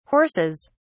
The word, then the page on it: horses: British English